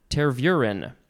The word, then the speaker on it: Tervuren: Now we have, I hope I say it right, Belgian tervuren. Tervuren. That's how it's pronounced. Capital T-E-R-V-U-R-E-N.